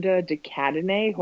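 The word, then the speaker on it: de Cadenet: And Amanda DeCadene, who I don't know how to say her last name, but if that is how you say it, that's how you say it.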